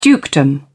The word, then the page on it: dukedom: British English